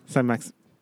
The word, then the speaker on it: Saint-Maximin: Saint-Maximin.